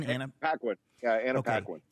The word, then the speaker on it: Paquin: Paquin? – Anna Paquin.